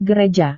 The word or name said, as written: gereja